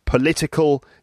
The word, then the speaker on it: political: And then the adjective political, the word stress is on the second syllable.